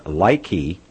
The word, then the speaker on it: Laiki: Well, here's the guy's actual account, and it's, I believe it's pronounced Likey, and it's actually Likey eBank, but if you read it, L-A-I-K-I, then they put the E before the bank. It looks like Lackey Bank to me.